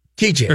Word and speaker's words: Tite: So the guy's name is spelled T-I-T-E. Tight. – Oh, tight. – Which is great, but it's spelled Chiche. – Chiche. – Or it's pronounced Chiche. – TJ, yeah. – But it's T-I-T-E. – T-I-T-E.